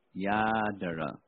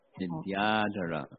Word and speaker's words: Vidyadhara: 他说，我之前实际上是一位 Vidyadhara，名字叫。Sudarshana pronounce Vidyadhara correctly, please. It's Vidyadhara. Vidyadhara.